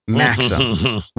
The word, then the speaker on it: Maxim: They have a brand there called Maxim, M-A-X-I-M, Maxim, which they pronounce, I'm going to do my best, Maxim.